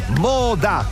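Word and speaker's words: Modà: Moda, Moda, con l'accento sulla A.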